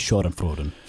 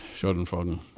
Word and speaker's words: Schadenfreude: That is Schadenfreude. – Schadenfreude.